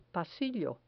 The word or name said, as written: pasillo